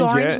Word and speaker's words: Gorringe: Yeah, Daniel Gorringe. – Gorringe like orange.